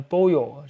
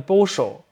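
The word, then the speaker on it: pollo: 蒙特维利亚，就那一串儿都号称是拉普拉塔河地区，他们还有那拉普拉塔河的拉普拉塔口音，西班牙语口音，这口音就是西班牙语的耶，就是西班牙语两个 L 念耶，什么 P O L L O 念 boyo 就是鸡的意思，但他们把那个耶都念成诗，所以他们 boyo 念 boso，什么野巴的念舍巴的，就是这样的。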